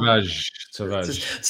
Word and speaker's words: Tavares: Anyway, Nuno Tavares. Is that how you say his name? – Tavares. – See, but before we move on to transfer. It's Nuno Tavares.